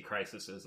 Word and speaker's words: crises: They talk about the crisis, but there's been multiple DC crises in the comic. Crises, if you will, in the comic book. – That is how you say that word.